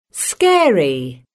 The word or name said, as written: scary